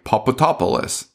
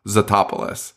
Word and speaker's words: Tatopoulos: So everyone calls him like Papatopoulos, and he's like, no. And then he says it's pronounced Zatopoulos.